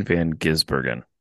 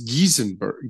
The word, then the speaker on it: Van Gisbergen: How many different ways are you going to pronounce this poor guy's name? It's Shane Van Gisbergen. It's Giesenberg, Giesberger.